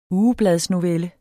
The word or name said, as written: ugebladsnovelle